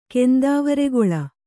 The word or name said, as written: ಕೆಂದಾವರೆಗೊಳ